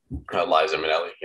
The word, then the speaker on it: Liza Minnelli: featuring Lizia Minnelli. Is that how you say it? – Liza Minnelli. Yep. – Liza Minnelli. Thank you.